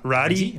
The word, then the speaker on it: Radii: R-A-D-I-I. – I think you got it right. Radi? – Radi, yeah. – R-A-D-I-I.